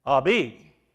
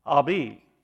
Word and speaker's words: Abi: They would say Abi. Abi. That's the intimate term that a child would use for his father in Aramaic, spelled A-B-I, and pronounced Abi, not Abba.